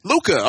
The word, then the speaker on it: Luca: Am I saying that right? Is it Luca? – Yes, Luca. – Luca. All right, cool.